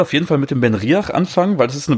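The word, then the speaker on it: BenRiach: Dann habe ich hier Ben Riach. – Ben Riach, ja.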